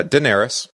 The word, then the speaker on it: Daenerys: Yeah, Daenerys.